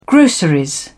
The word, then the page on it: grocery: British English